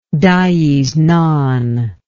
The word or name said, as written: dies non